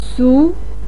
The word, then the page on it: su2: pinyin